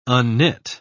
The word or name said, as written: unknit